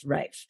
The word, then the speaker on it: Ralph: So one of them, tell us about Victoria, who's the girlfriend of one of them, whose name is spelt Ralph, but of course pronounced Rafe.